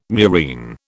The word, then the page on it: murine: British English